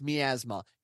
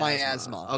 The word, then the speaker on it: miasma: I was like, so when I first saw that word, I thought it was pronounced miasma. It is not. It is pronounced miasma. – Okay. – Trust me, I am not going to mispronounce the name of the show. I'm not going to mispronounce the name of something very important. It is miasma.